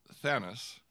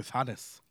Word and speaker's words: Thanos: So in the UK, it's pronounced Thanos. – Thanos? – Thanos. – Oh, Thanos. – And in America, it's Thanos.